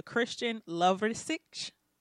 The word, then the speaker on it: Christian Lovrecich: So we've been talking to Christian Lovrecich.